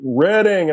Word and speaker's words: Reading: One time I did a show in Reading, Pennsylvania, and I said, "Thank you, Reading," because it's spelled R-E-A-D. And they were like, "Reading."